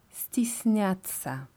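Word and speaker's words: стесняться: Also sich scheuen oder sich genieren heißt stisnyatsa.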